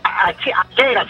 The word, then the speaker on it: Ashera: No, hay una raza de gatos que vale entre $22,000 y $100,000, que es la achera, se llama. Se escribe A-S-H-E-R-A.